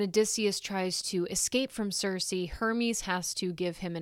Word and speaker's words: Circe: But yeah, I believe the correct pronunciation is Circe.